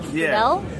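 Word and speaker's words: Friedle: Yeah, okay, that's Will, how do you pronounce his name? Friedel? – Friedel. Oh, that's right, okay.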